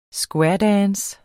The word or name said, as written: squaredance